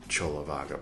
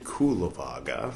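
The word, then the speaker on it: Cullavagga: Pay no attention to how I pronounced it, Kulavaga, for the first long time.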